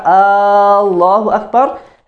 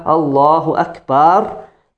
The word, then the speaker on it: Allahu Akbar: So when you say in the salah, Allahu Akbar, that's incorrect. That's not a valid takbir. Also, the ba. Don't pull the ba. Allahu Akbar. Bar is not the same thing, it's a different meaning. Right? The pronunciation is important.